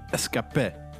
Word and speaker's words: Ska-P: En eh, op Wikipedia staat dat ze eigenlijk moet uitspreken als escapé. Escapé. Escapé. Ja, oké, okay, Ja is niet als schaapjes.